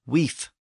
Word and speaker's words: wif: In Old English, the word was spelled W-I-F or win-I-F, and it was pronounced weef with an F sound at the end.